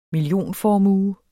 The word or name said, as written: millionformue